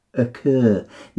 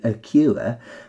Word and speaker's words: occur: Now, that's often a difficult one because although you want to pronounce the U as a U and say occur, on this occasion, it combines with the R to make an er sound, like so. Occur.